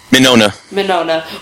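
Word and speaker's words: Mignogna: Vic Mignona, is that how you say it? – Minona.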